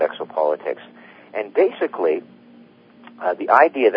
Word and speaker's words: Exopolitics: Are you going to tell us what Exopolitics, did I pronounce that right? Exopolitics, yeah.